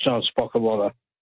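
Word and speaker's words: Ron Spocelota: But it's pronounced John Spokalotta. It's R-O-N-S-P-O-C-E-L-O-T-A.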